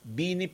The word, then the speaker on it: Bini: Ang na-pronounce ko is Benny. Bini pala. Anggi, ang pag-pronounce ba kaning girl band na ni is Bini? Tama?